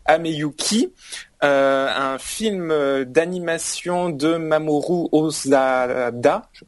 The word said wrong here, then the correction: Hosoda: Hosoda, euh, Osoda. Osoda. Hosoda. Oui, c'est de l'eau. Le, H est... mélangé de l'eau et le H est sonore, donc c'est Hosoda.